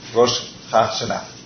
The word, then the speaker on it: Rosh Hashanah: una festividad judía que se llama Rosh Hashanah. Rosh, ¿qué? ¿cómo se pronuncia? Hashanah. Rosh Hashanah.